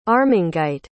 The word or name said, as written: armangite